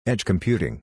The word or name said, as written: Edge Computing